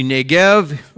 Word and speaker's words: Negev: It's actually Negev, that they pronounce it a different way.